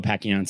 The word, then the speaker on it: Pacchione: It's Michael Giancarlo Pacchione. So Mike Pacchione, Pacchione, if you actually want to sound Italian, that's the true pronunciation, but we've turned it into Pacchione.